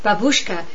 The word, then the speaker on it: babushka: Babushka in Russian, or its correct pronunciation is babushka, means granny.